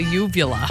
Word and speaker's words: uvula: Your ulva. – That's a uvula. – Oh, uvula. – So should I say ova or uvula? – It's uvula. That's okay, how it's pronounced.